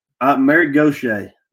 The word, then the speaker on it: Mary Gauthier: Mary Gauthier.